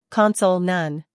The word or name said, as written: console